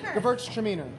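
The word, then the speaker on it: Gewürztraminer: Gewurz Treminer. – Gewurz Treminer. – Or you could say girls are meaner.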